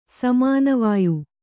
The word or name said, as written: ಸಮಾನವಾಯು